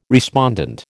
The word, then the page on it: respondent: American English